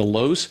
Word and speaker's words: Louhs: We are on Facebook, Twitter, Instagram, and YouTube, all under the headings of The Lowes. And that last name is spelled L-O-U-H-S. That's The Lowes.